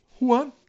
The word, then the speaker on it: Wuhan: Wuhan.